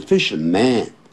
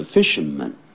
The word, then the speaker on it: fishermen: 他会保护这些渔夫，也就是捕鱼人，因为这是复数，就不要念成 fisherman，叫 fisherman 啊，这样子发耳钉。